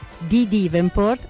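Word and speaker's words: N'Dea Davenport: Si parla appunto dei primi anni 90, la voce è quella bellissima di Dee Devenport, che si dice così ma si scrive tipo codice fiscale.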